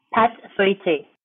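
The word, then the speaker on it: pâte feuilletée: Pâté feuilleté. I mean, really, what was I trying to say there? Thank you very much. Can you say that without me talking over you? – Pâté feuilleté.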